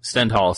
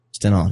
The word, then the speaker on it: Stendhal: I did want to mention briefly about the Stendhal syndrome. Am I pronouncing that correct? – Stendhal syndrome. – Stendhal, yes.